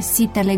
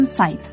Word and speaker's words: site: Scriem site, dar pronunțăm site, cu toate că normele ortoepice ale limbii române nu prevăd ca i să fie pronunțat ai.